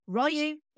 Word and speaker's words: Ryu: aka Ryu, in this movie, a pronunciation that has stuck ever since, but technically it is Ryu, Grand L.